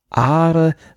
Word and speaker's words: Argyle: Ich habe übrigens gefunden hier, ich habe die Aussprache gefunden. – Jetzt kommt's. – Argyle. Argyle. – Okay. – Wobei das nicht G und das L hinten im Hals wie im Rheinland gesprochen werden. Also Argyle.